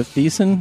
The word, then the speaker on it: Thiessen: Tiffany Amber Thiessen, is that how you say her name? – Oh, yeah, from BH9.